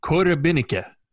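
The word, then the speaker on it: Korobeiniki: Give it the accurate Russian pronunciation is Korobinike, which is, I had it right here.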